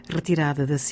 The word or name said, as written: rretirada